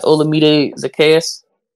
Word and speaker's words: Olamide Zaccheaus: They got Olamide Zaccheaus. I believe I pronounced that right. – Yeah, you did.